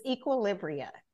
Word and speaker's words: Equilibria: It's Equilibria.